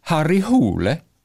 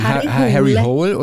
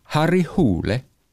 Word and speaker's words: Harry Hole: Und sein Kommissar wird übrigens ausgesprochen Harry Hole. Weil viele sagen Harry Hole oder Harry Hole, aber er wird ausgesprochen Harry Hole.